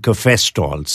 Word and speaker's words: cafestol: K-A-H-W-E-O-L, caviol. And then there's something called cafestol, C-A-F-E-S-T-O-L.